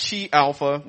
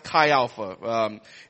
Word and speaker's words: Chi Alpha: So if you guys, by the way, it's not Chi Alpha. We're not Chia Pets. It actually is pronounced Chi Alpha.